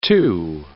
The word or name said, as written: type 2 diabetes